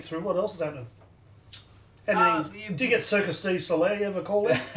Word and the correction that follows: Cirque du Soleil: Did I even say that right? – Cirque du Soleil.